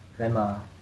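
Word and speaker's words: Gemme: It's G-E-M-M-E, but you pronounce it Emma, whatever the G, and I mean, sure.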